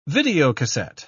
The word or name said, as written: videocassette